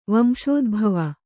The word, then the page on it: ವಂಶೋದ್ಭವ: Kannada